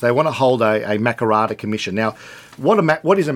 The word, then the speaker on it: Makarrata: How you say it? We're doing our best. – We're doing our best. – Makarrata? – Makarrata. Yeah, the Makarrata Commission.